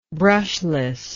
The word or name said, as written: brushless